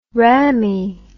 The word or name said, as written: rammy